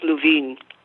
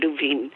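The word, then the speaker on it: Lewin: It's L-O-E-W-N, in something, but it's pronounced Levine. Moshe Levine.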